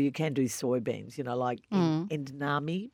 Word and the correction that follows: edamame: Is that how you pronounce it, beans? – Edamame.